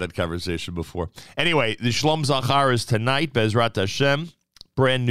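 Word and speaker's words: Shalom Zachar: Remember, Mayor Weingarten always taught us that the proper pronunciation is Shlom Zachar.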